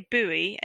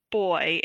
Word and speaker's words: buoy: I think one that came up was, so I would say buoy and he would say boy.